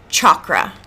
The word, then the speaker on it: chakra: And is it actually chakra? – It's chakra. – Chakra. – Yeah. – Okay. – The H is silent.